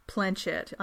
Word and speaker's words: planchette: It's pronounced planchette and not like planchette or something like planchette.